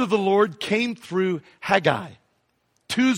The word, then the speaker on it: Haggai: Okay, turn in your Bible to this little book called Haggai. Or some people pronounce it Haggai.